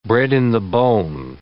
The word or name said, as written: bred-in-the-bone